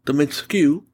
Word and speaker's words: Dimitrescu: Maggie Robertson as Alice Nia Dimitrescu. That is how you pronounce the name.